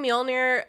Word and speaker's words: Mjolnir: He's just thrusting Mjolnir up in the air. And also, I'm going to mispronounce that word every time. – You just nailed it. – Thank you. – No, you nailed it.